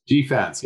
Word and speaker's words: GFANZ: Is it GFANZ? – GFANZ, yes. Yeah, I like your pronunciation, Ari.